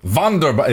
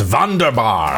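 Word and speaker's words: Wunderbar: Vonderbar. It's pronounced Vonderbar.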